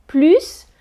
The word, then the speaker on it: plus: On commence tout de suite avec la prononciation du mot plus dans une phrase négative. Quand le mot a un sens négatif, on ne prononce pas le S final. On prononce plus. Plus ne plus.